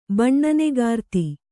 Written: ಬಣ್ಣನೆಗಾರ್ತಿ

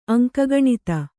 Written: ಅಂಕಗಣಿತ

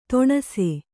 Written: ತೊಣಸಿ

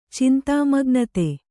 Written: ಚಿಂತಾಮಗ್ನತೆ

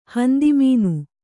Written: ಹಂದಿಮೀನು

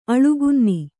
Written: ಅಳುಗುನ್ನಿ

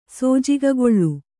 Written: ಸೋಜಿಗಗೊಳ್ಳು

